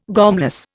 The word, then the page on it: gormless: Indian